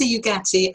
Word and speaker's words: Ugati: Vanessa Ugati, it's a lovely Italian pronunciation there, and I've got a little bit of Italian in us. Tell us your story, Vanessa, and what can we learn from what's going on right now? – Thanks, Rob. Very impressed by your pronunciation. I must say, you go to the top of the class on that one.